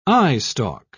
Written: eyestalk

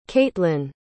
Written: katlynn

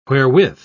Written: wherewith

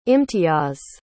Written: imtiyaz

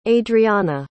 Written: adriana

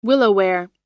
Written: willowware

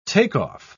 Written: takeoff

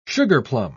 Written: sugarplum

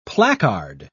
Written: placard

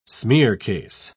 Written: smearcase

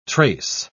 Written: trace

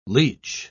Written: leech

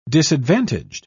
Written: disadvantaged

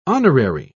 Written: honorary

